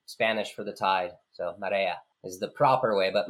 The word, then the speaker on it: Marea: It's actually pronounced Marea which means it's Spanish for the tide.